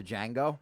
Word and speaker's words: Django: It was just all the times Leonardo DiCaprio said the N-word into Django. – Hell yeah. – It was just in a row. – It's Django. This D is silent.